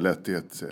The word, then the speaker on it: Lehtihet: Du, eh, uttalar du ditt efternamn Lättighet? – Ja, jag säger nog Lättighet. – Lättighet, ja rätt ska vara rätt.